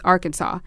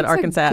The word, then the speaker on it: Arkansas: No, it's Arkansas, where you have to pronounce Arkansas correctly. Because you can't say Arkansas or Arkansas.